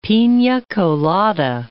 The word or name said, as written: pina colada